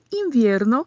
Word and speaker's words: invierno: E invierno, que é a característica diferente aí da pronúncia do V, né? Como comentamos no outro áudio falando de viernes, o V tem um som de B, não um B, né, forte, marcado, mas é um som de B.